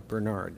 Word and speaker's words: Bernhard: It's B-E-R-N-H-A-R-D, Bernhard or Bernard is maybe the way you pronounce it, okay?